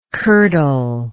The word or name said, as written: curdle